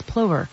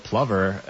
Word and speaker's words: plover: I feel like everyone was saying plover, but then there's a bunch of videos of people specifically saying, it's not plover, it's plover.